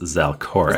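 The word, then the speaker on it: Zalcoré: That's Z-A-L-C-O-R, and then the E with an accent over it. I've decided that it's pronounced Zalkore.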